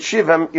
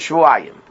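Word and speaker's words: Shvu'ayim (שבעים): So therefore, you don't read it Shivim, you read it Shvu'ayim. – Is it double Yud? – It's written, Shin Beis Ayin Yud Mem. – One Yud. – So you would read it Shivim. The way it's written, it's Shivim. But how do we pronounce it? Shvu'ayim.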